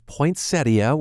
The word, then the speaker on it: poinsettia: And yes, we discovered the correct pronunciation is poinsettia, where I say the I at the end.